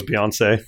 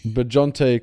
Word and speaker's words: Bjarne: That is not how you say his name. – I thought it was Beyonce. – It's Bjarne Christensen.